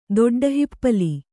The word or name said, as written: ದೊಡ್ಡಹಿಪ್ಪಲಿ